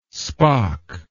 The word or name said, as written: spaak